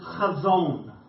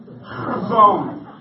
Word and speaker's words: chazon: Right, the word for vision that is used here is the Hebrew word chazon. Everyone say that with me. Chazon. No, you gotta roll it. Chazon. It helps if you spit, then get a little bit of phlegm in your throat to pronounce the Hebrew.